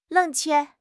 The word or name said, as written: 冷却